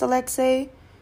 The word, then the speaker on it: Alexei: But I truly do appreciate the people that see it and automatically somehow know that it's pronounced Alexei.